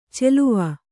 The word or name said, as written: ಚೆಲುವ